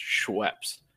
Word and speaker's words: Schweppes: So tasteability, and I'm going to compare this to, like, you know, your standard like Canada Dry or Schweppes, which is how it's pronounced. Schweppes.